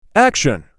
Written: Action

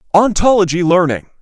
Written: Ontology learning

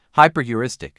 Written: hyper-heuristic

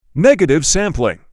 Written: Negative sampling

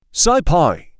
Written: SciPy

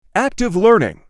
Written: active learning

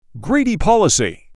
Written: greedy policy

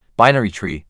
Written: Binary tree

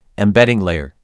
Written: Embedding layer

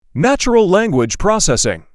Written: Natural language processing

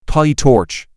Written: PyTorch

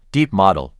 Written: Deep model

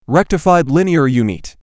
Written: Rectified linear unit

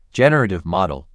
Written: generative model

